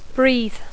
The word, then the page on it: breathe: English